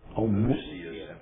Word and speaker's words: Homoousios: Homo Homoousios. – Homo Oestius.